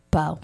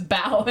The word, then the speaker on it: Bo: How do you spell Bo? – B-O. – Okay. – Bow. – I didn't know if it was bow and you were pronouncing it. No. Deluxe Green Bow Restaurant. – Okay. – Nope, I'm not wrong on this one.